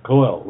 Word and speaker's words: Koel: Koel, I pronounced it right. Koel, right? – You did pronounce it right, yes.